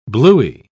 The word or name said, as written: bluey